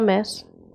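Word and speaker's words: Mads: Se pronuncia Mets. – Mets Mikkelsen.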